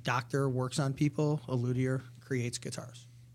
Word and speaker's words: luthier: So apparently I didn't know there was a silent H in luthier and it's luthier, but I guess there's a grammar lesson for all of us in this today, so we get something out of it.